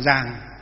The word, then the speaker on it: dường: Miền Bắc thì là phả cúng dường chân ngôn, miền Nam là phổ cúng dường chân ngôn. Miền Bắc chúng ta đọc là giàng, cúng giàng, miền Nam đọc là cúng dường.